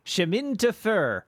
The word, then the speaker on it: Chemin de Fer: It's Chemin de Fer? – Yeah. – Is that right? – I believe so, yes. – Which I will now have my pronunciation corrected of, I'm sure. Oh, it was right.